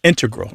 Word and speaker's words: integral: But the mathematicians and math using scientists are more likely to say integral with the stress on the first syllable.